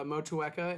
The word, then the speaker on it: Motueka: It's Motueka, it's Nelson, it's Raka, and they're all three hops from New Zealand.